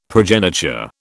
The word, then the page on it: progeniture: British English